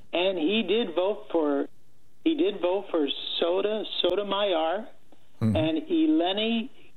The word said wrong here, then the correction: Elena: if I'm pronouncing her name right, Elena Kagan.